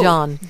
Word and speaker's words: Jaan: And it's pronounced Jan, by the way. – Sorry. – Oh, Jan.